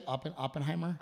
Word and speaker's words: Oppenheimer: Is that how you say it? Oppenheimer? – Uh-huh.